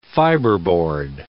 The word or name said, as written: fiberboard